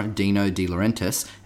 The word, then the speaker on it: Dino De Laurentiis: I knew I'd get his name wrong. Laurentiis. Produced by cult cinema master Dino De Laurentiis and directed by B-grade movie legend Mark L.